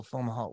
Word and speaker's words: Fomalhaut: Fomalhaut.